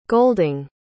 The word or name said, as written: golding